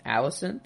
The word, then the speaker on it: Alicent: Or Otto sees how close Rhaenyra and Alicent, is that how you say it? – Yeah, yeah.